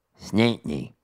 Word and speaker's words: St. Anthony: So if you're from a town in Newfoundland called St. Anthony, it becomes Snantney, almost like one syllable.